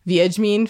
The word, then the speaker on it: Wiedźmin: I've since become aware that apparently the very cool thing for us Wiedźmin fans is that... Did I butcher that? You said it right. – Good job. – Apparently the very cool thing for us Wiedźmin fans is that we're supposed to be like really angry at the Netflix series for adapting it wrong.